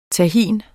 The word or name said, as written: tahin